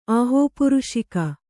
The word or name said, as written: ಆಹೋಪುರುಷಿಕೆ